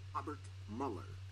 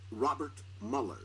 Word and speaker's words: Robert Mueller: Well, I always thought it was Mueller, like Ferris Bueller. – Yeah. – But it's not.